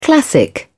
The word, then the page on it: classic: British English